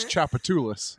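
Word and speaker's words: Tchoupitoulas: No, it's Chapatoulas. – Mm-hmm. – You're saying it wrong. Chapatoulas.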